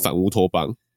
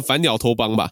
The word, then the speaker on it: 反乌托邦: 反正我看到就是乌托邦、异托邦、反乌托邦都有人写，那我觉得最有可能应该就是反乌托邦这样子。各位应该知道反乌托邦是什么吧？不会念成反鸟托邦吧？ – 反。